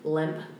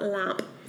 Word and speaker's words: Lemp: And when I say lemp, it's spelled L-E-M-P, just for reference. – It's not lamp. – It's not lamp mansion. – I love lamp. – I love lamp. It's lemp.